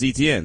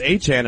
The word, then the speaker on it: Etienne: But either way, when Jacksonville drafted him, that's all we ever heard was, oh, that's Travis Etienne.